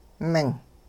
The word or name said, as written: ming